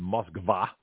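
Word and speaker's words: Moskva (Москва): So both Kiev and Moscow, which is pronounced Moskva by Russians, Kiev and Moskva have seen significant progress in negotiations.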